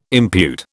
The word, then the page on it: impute: British English